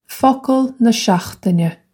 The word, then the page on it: Focal na seachtaine: foc-ul nuh shokhtina; Irish